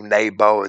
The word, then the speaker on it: Nabu: Bel boweth down, Nabu stoopeth, Nabu Nabu, Nabo, it's spelled several different ways. The vowels are always negotiable in Akkadian or Aramaic or Hebrew.